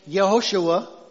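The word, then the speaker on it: Yehoshua (יְהוֹשֻׁעַ): The name Joshua is actually a Hebrew name, Yehoshua. Yehoshua, right? Okay, that's how it would have been pronounced. They didn't have the J sound.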